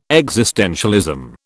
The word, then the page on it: existentialism: British English